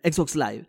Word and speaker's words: Live: el día de lanzamiento, se cayó Xbox Live o Live, nunca supe cómo se pronuncia. – Live, live. – Live, Bien, Xbox Live.